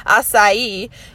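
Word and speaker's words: açaí: Things like acai bowls. I think I'm saying that right. It's spelled like aca bowl, but I think it's pronounced acai, you know, because it's so bougie, the acai bowl.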